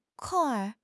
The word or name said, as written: kong4 r5